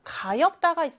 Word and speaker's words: 가엾다: 그 다음, 가엾다가 있어요. 가엾다. 가엾다. 가엾다 역시 겹받침 비읍 시옷이 쓰였는데 앞에 있는 자음, 비읍의 소리가 나서 가엾다 라고 읽어줍니다.